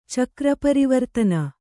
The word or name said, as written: ಚಕ್ರಪರಿವರ್ತನ